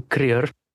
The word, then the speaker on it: Kruger: So, my name is pronounced Hugo Krier. But Hugo Kruger is fine for people from English speaking countries.